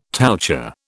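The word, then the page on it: touché: British English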